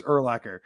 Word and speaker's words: Urlacher: It's Urlacher.